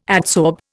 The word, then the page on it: adsorb: Indian